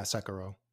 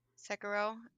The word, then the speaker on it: Sekiro: So is there, because I've never played this game before, is it Sekiro? Is that how you say it? – Yeah, Sekiro. – Sekiro.